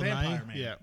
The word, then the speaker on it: Nighy: Okay, the Bill Nighy, is that how you say it? – Yeah, Bill Nighy.